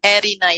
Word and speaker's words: Erina: Is it Erina? – Erina, yes. – Erina, excellent. – That's correct. Erina.